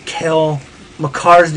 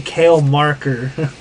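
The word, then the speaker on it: Cale Makar: Is when I butchered Kale McCarr's name, I say Kale Marker.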